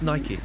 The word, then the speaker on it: Nike: Is that how you pronounce it? Oh, no, it's Nike. – Nike.